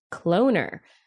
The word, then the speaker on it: CloneR: Cloner, rhymes with donor.